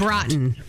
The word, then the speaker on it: Braaten: B-R-A-A-T-E-N. – B-R-A-A-T-E-N. How would you pronounce that? – Broughton. – I would say Brayton.